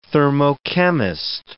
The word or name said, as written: thermochemist